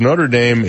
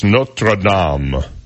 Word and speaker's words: Notre Dame: Um, the, actually, it's Notre Dame in Indiana. In Paris, it's Notre Dame. That's how you say it.